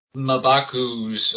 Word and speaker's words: Mbaku: And, of course, it's, what is it, Winston Duke's, Mbaku's role. – Mbaku. As they pronounce it in the movie.